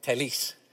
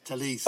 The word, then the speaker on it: Telles: And she corrected me, Telles. – Telles. – But I didn't know that, you know, because there's a Portuguese pronunciation of it and then there's a Southern Brazilian pronunciation of it.